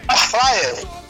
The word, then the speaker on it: air fryer: Air fry, né? – Aquela, né? É fryer. Você pronuncia bonitinho. Fala de novo. – Air fryer.